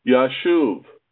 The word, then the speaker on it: Yashuv: So Issachar gave his son one of the shins from his name and renamed him Yashuv, which means to return. Hence, his name is pronounced as if it were written with only one shin.